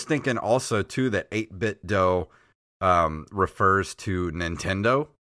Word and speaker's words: Do: Since the theme of the company is retro, the 8-bit is referenced to classic games, and I believe the do in the name is pronounced like do, D-O-E, as in a martial arts school.